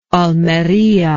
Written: Almerian

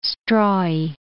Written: strawy

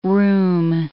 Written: room

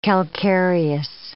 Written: calcareous

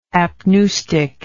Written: apneustic

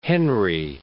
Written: Henry, Cape

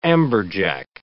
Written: amberjack

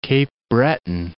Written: Breton, Cape

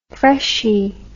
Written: freshie

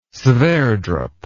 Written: sverdrup

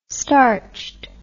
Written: starched

